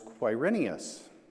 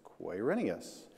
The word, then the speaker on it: Quirinius: and how you pronounce his name is Quirinius. So it's kind of like why, except it's Quirinius, the governor of Syria, right?